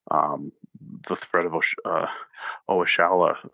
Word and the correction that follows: Oshoala: I totally butchered that. Oshoala from Nigeria.